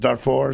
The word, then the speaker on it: Darfur: Is it Darfur? Is that how you pronounce it? Mm-hmm. I want to make sure I get it right.